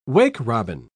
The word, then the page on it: wake-robin: British English